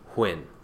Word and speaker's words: Huynh: If you ever were curious on how to pronounce that last name, Huynh, it is not the same as Nguyen, which is Nguyen. It's actually pronounced like Hwin, Win with a H, Huynh.